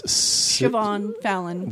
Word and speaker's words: Siobhan Fallon: Siobhan Fallon.